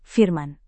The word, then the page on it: firman: indonesian